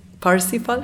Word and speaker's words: Parsifal: And it was Wagner's opera, Parsifal, is that how you pronounce it? – Parsifal? – And, thank you.